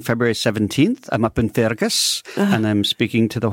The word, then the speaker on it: Fergus: You've got to pronounce it Fergus, lassie.